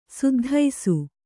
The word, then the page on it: ಸದ್ದಿಸು: saddisu